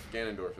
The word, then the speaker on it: Ganondorf: Gandalf? – Ganondorf, there we go.